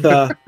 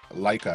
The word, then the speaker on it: Leica: Well, it's with Leica, Leica. – Oh yeah, the Leica, I think is how it's pronounced.